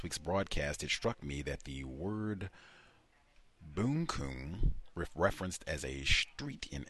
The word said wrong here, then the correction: bunkum: Oh, bunkum. Pronounced it incorrectly. Bunkum.